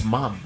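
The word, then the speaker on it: ma'am: Just take the D out and put in some like an apostrophe or something. That's how it's said. It's mom. It's a short form.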